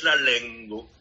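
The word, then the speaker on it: lenga: La lengua, qui estas la lingua, do facte ciui, prescau ciui la inai vortoi, finigas per litero A, sed oni prononcas O. Estas specialajo. Do oni diras la lengvo, kaj pro tio, tiu sonas kiel Esperanto, ĉar la artikolo estas la, kaj oni diras O je la fino.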